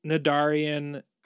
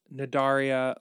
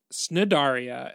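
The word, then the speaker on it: Cnidaria: We haven't quite branded it yet, but it's where I go deeper into the Nidaria life. And you'll notice that the C is silent and that Snidaria has been wrong and that it's closer to Nidaria.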